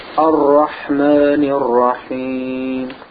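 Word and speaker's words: Ar-Rahmanir Rahim (الرحمن الرحيم): And notice, yes, and notice the Ra has a Shadda on it. So both of the Ra's, Ar-Rahman, Ar-Rahmanir Rahim, Ar-Rahmanir Rahim. Notice how I pronounce the Ra's twice.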